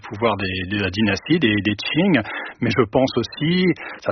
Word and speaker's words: Qing: Donc vous dites Qing, hein, pour Qing, c'est Qing, la prononciation chinoise, à laquelle on n'est pas contraint d'ailleurs.